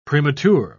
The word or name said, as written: premature